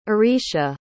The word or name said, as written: aricia